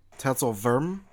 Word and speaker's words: Tatzelwurm: If you want to be technical with this, then you can pronounce it Tatzelwurm, because in German... – One more time? – Tetzelwurm. – Tetzelwurm. – Because the W was pronounced as a V in German.